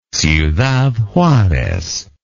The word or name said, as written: ciudad juarez